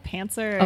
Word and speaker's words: pantser: Yep, pantser okay.